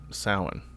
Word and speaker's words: Samhain: Ooh, pronounced correctly as well. – Yeah. Unless I'm talking about the Glenn Danzig band, because I think they call that Sam Hain. I do believe they call it Sam Hain.